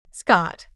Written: Scott

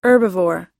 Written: herbivore

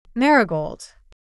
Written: marigold

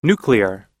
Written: nuclear

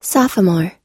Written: sophomore

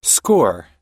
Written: score

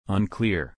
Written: unclear

